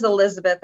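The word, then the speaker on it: Elisabeth: So my name is Elizabeth, and it's spelled with an S.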